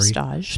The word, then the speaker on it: stage: or they call it a stage in culinary, a stage. – Spelled like stage, mm-hmm. S-T-A-G-E, with a world-renowned celebrity chef.